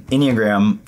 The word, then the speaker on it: Enneagram: Yeah, I mean, Enneagram, first and foremost, rhymes with pentagram.